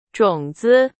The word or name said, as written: 种子